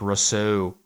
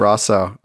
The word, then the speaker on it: Rousseau: Yeah, on Lake Rousseau, I think is the pronunciation.